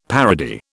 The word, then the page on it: parody: British English